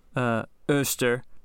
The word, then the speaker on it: Eostre: These people are all pagan, and they have a goddess named Oster. – Spell that for me. – So, E-O-S-T-R-E. – Oster. Slower? – E-O-S. Us. – Okay. – T-R-E. Ter.